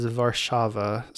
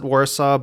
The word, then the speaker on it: Warszawa: So if you want to say Warsaw like a Polish person, then how you say it is Warszawa. So it's with a V. The two W's make the V pronunciation. So everyone calls it Warsaw, but just be aware that as the Polish pronunciation, it is Warszawa.